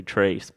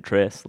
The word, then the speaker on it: Tres: And just the way I was always saying it, it still sounds like I say Trace, but I'm really saying Tress, like T-R-E-S, like it's spelt.